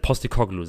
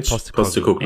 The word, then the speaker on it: Postecoglou: Unterstützer von wie spricht man jetzt? Also Postekoglu sagen wir, oder? – Ja, ich. Also ja. Das, jetzt, Ange Postekoglu.